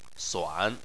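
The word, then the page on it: suan3: tone 3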